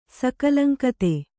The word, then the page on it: ಸಕಲಂಕತೆ: Kannada